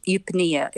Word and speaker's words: eupnea: But it's the medical term eupnea.